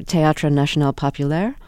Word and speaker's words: Théâtre National Populaire: He was the director of the, I'm sorry for my pronunciation here, Théâtre National Populaire.